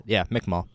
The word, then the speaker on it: Mi'kmaq: The Mi'kmaq. I'm sorry. I mispronounced that. Yeah, Mi'kmaq.